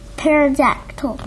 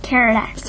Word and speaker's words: pterodactyl: Pterodactyl. – But you said it was a what? – Pterodactyl. – So the P must be silent, huh? – Yeah.